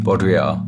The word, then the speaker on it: Baudrillard: Baudrillard.